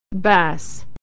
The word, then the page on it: bass: American English